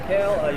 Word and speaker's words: Haile: I'm Eric Hale.